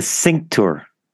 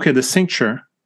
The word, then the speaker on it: cincture: What is the, and correct me if I'm mispronouncing it. – You're mispronouncing it. – The cincture. – The cincture. – Cincture.